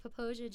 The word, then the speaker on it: Popo Agie: In my defense, it is spelled P-O-P-O-A-G-I-E, so it looks like Popo Agi, but Popoja just sounds like a fancy, like... – It very much looks not how you say it.